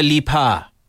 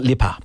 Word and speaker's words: Lipa: I was saying her name wrong for years. It is Dua Lipa. Not Lipa.